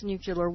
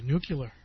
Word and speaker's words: nuclear: nuclear war instead of, voting these people out of office. – You're even pronouncing it correctly, by the way. Nuclear.